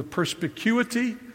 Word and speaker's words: perspicuity: Baptists believe that the scripture is a fancy word used historically, the perspicuity of scripture. Perspicuity. Five syllables, which is unfortunate because what it means is that the Bible is clear.